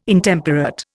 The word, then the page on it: Intemperate: Indian